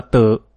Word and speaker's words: tử: Chữ tím tiếng Hán Việt đọc là tử,